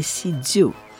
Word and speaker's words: sijo: Alors moi je dis Sijo, mais normalement c'est Sijo. Ça s'écrit S-I-J-O.